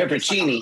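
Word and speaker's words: pepperoncini: I get the hot Polish sausage with the pepper connie. How do you say it? Like olive. – Peppercini. – Peppercini. Yeah, yeah.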